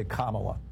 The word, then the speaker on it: Kamala: So say this word, comma. – Comma. – Okay. Now say Kamala.